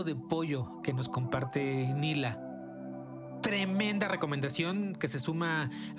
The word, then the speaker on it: Neela: Si la buscan, Nila se escribe N-E-E-L-A, Nela, pero se pronuncia Nila.